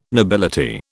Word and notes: nobility: British English

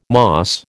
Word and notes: moss: American English

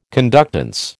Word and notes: conductance: American English

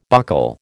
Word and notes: buckle: American English

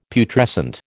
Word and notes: Putrescent: American English

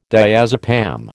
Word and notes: diazepam: American English

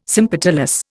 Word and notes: sympetalous: Indian

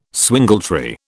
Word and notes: swingletree: British English

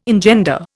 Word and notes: engender: Indian

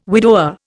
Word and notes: widower: Indian

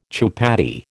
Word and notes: chupatty: American English

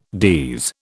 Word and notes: DES: British English

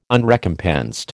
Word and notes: Unrecompensed: American English

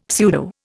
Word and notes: pseudo: Indian

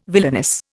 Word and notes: villainous: Indian

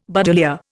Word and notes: buddleia: Indian

